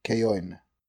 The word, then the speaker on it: Caoin: C-A-O-I-N. – Just for clarification, do you pronounce the N? Because it sounded like you said Kaoi. – I pronounced the N. – Okay, Kaoin.